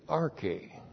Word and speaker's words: arche: And then in this couplet, the second couplet, we have also arche. A-R-C-H, long E, arche.